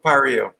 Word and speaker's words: Paria: Is that how you guys pronounce it? – Gulf of Paria. – Paria. Paria.